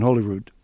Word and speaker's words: Holyrood: I wanted to know if there was a roll in Holyrood. – Roll your R's if you want to be proper Scottish, but yeah, it's a Holyrood if you want to.